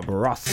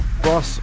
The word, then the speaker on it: Bros: Before that, we had Something Got Me Started, and then I Owe You Nothing from Bross. Bross, even though they're bros, but apparently it's pronounced Bross.